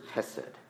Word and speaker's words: hesed: The word for loving kindness in the Hebrew is hesed. It's a strong word. You got to get that guttural in it if you're going to say it right in the Hebrew.